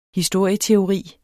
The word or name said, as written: historieteori